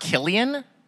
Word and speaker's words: Cillian: You know what's pronounced Killian? – Killian Murphy. – Killian. – That's it's a C, but it's Killian.